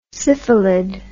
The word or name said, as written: syphilid